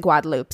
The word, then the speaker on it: Guadeloupe: So when I read this, it looks like Guadalupe to me. It's spelled like Guadalupe, but when I listened to the French Creole pronunciation of the island, they pronounce it Guadalupe. So that's how I will pronounce it.